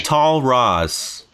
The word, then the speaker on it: Tal Raz: Tal Raz. – T-A-H-L. Tal, like, rhymes with Paul, but it's an Israeli name. R-A-Z.